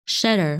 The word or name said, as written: Schedar